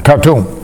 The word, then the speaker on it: Khartoum: Starts with a K-H-A-R, Khartoum? – Khartoum.